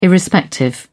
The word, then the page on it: irrespective: British English